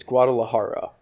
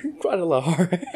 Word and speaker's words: Guadalajara: They say I'm from Guadalajara. Is originally where my bloodline's from, so... Si, senor. – That's awesome, but it's Guadalajara. You said it like Peggy Hill would pronounce it. Guadalajara.